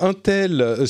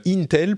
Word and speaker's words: Intel: Il y a Intel sur lequel on a des informations. Intel plutôt, il faut bien prononcer.